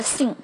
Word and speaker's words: think: that，那类似的发音呢，就是这样的一个清辅音，think、 three、 author、 healthy、 birth、 path，都是这样的一个清辅音的发音，也是要注意你的舌头的位置，OK，不要把 think 发成了 think。一定要把舌尖拿出来，才构成了一个正确的。